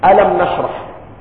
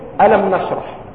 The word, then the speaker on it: ألم نشرح: أنت الآن لو نظرت في سورة البقرة ألف لام ميم، تمد ألف لام ميم، وفي سورة الشرح ألف لام ميم، ولكنك تقول ألم نشرح.